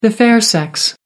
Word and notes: fair sex: American English

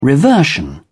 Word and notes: reversion: British English

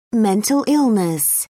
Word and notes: mental illness: British English

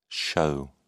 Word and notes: shew: British English

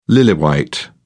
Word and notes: lily-white: British English

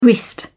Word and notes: wrist: British English

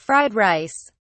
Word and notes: fried rice: British English

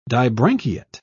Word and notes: dibranchiate: British English

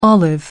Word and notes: olive: American English

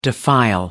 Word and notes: defile: American English

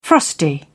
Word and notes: frosty: British English

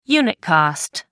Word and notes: unit cost: American English

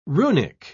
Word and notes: runic: British English

